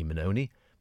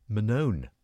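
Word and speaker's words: Manone: I always thought he was Wingy Minoni, but no, you don't pronounce the E at the end. Apparently he was just known as Wingy Minone. Okay, see, I stand corrected on that one. Not Minoni, Wingy Minone.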